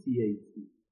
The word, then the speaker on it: CAC: کاستومر اکویزیشن کاست ما تلفظش میکنیم CAC C انگلیسی سی A اولین حرف انگلیسی دوباره